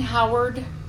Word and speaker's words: Howard: Doreen Howard, H-O-W-A-R-D. And my first name is spelled D-O-R-E-E-N.